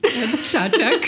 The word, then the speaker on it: Čačak: Čačak.